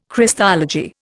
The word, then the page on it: Christology: Indian